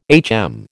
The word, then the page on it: HM: American English